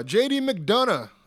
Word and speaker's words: McDonagh: Uh, JD McDonagh.